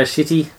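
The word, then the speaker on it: Schiti: Guardians of the Galaxy Volume 4, Brian Michael Bendis, Valerio Shitty. – And you, I swear that's how it's pronounced.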